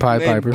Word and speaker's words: Pied Piper: Her parents had a press conference stating that her daughter is trapped in a sex cult by none other than the Pod Piper, or the Pied Piper, whatever he calls himself, R. Kelly. – The Pied Piper. – Pied Piper was right. You said it right.